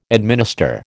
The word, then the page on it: administer: American English